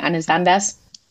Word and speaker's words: Anne Sanders: Da kommen wir gleich nochmal zu dem Namen zurück und dann hast du aber veröffentlicht, hast du Anne Sanders oder Anne Sanders? Das kann man ja beides eigentlich so aussprechen. Was war da die richtige? – Ja, ich denke, aus dem Grund wurde der Name gewählt, aber ich würde sagen Anne Sanders.